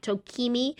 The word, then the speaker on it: Tokemi: It's spelled T-O-K-E-M-I, Tokimi, and then health.